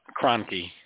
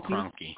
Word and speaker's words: Kroenke: Kroenke. – Stan Kroenke.